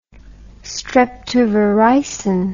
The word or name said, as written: streptovaricin